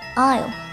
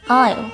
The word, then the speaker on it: aisle: Aisle, aisle，走廊、过道，不是爱所，也不是爱所。词中间的字母 s 不发音，读作 aisle。